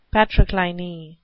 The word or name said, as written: patrocliny